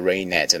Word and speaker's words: rained: Então ele fala, if it rained, olha a pronúncia aqui, rained, você não pode dizer rained, não, é rained, é como se esse E não existisse, rained, and it was hot, o T quase não sai, it was hot outside.